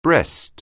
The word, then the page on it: breast: anglais